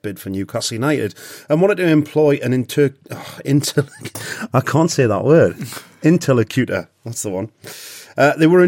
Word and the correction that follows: interlocutor: You're putting the emphasis on the wrong syllable. – The emphasis, interlocutor. Yeah, interlocutor.